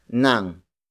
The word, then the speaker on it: nang: The next word is nang. Nang. Nang. Nang is spelled as NG.